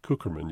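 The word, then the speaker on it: Cukierman: But our guest today, Rabbi Rogério Kukerman, I might spell your last name, it's C-U-K-I-E-R-M-A-N, but you pronounce it Kukerman, you told me.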